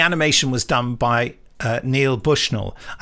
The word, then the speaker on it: Neil: I think his name's pronounced Neil, but it's spelt with the E and the I around the other way, so it could be Nile. So sorry, Neil or Nile.